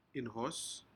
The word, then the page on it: inhos: Aneityum